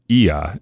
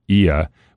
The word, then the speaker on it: Oia: And when I went, my wife and I had the opportunity to visit, and we stayed in, I believe it's pronounced Ia. It's spelled O-I-A, but they pronounce it Ia, which is in the kind of northwestern part of the island.